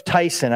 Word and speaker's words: Tson: Joseph, his name is spelled T-S-O-N, pastor in Romania in the 70s. So Joseph Tyson, I would say, a Romanian pastor, he served at a time when the secret police were orchestrating a vicious campaign against his particular church family.